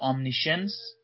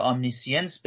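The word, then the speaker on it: omniscience: امنیشنس یا امنیسینس به تلفظ دقیق‌تر، امنیسینس یعنی چی؟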